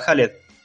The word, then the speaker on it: Khaled: Hola, Jalet. ¿Cómo andan chicos? Toda la mesa, un gran placer estar con ustedes al aire y gracias por pronunciarme tan bien el nombre. Siempre me lo pronuncian mal, la verdad que excelente.